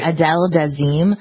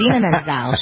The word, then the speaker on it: Idina Menzel: That's how John Travolta mispronounced it. Adele Dazeem. – Yeah. – It's Idina Menzel.